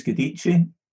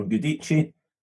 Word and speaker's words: Guidici: So my name's Guidici, or Guidici, but most people know me as Steve G.